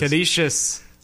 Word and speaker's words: Canisius: The next team behind Louisville is Canisius. I think I'm saying that right. – It is Canisius. – Canisius.